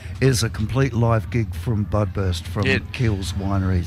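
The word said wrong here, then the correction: Kies: Keys. Yeah, it's spelt K-I-E-S, but I've been told Keys.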